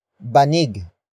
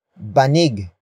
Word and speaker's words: banig: And that is banig. Banig.